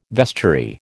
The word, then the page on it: vestiary: American English